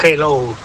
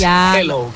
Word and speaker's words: hello: Y la segunda, dile a Elena que su dicción en castellano es la más perfecta que conozco, pero que cuando diga hello, que no pronuncie la J, que no es hello, que es hello, que la aspire la J y no pronuncie la J potente castellana.